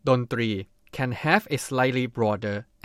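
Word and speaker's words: Dontri (ดนตรี): Say it together with me, Dontri. Dontri is a Thai noun, consists of two syllables. The syllable 3 needs to be pronounced similar to the R sound in English.Another fun fact is that the word 3 actually means try or number 3 in Thai.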